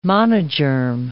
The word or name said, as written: monogerm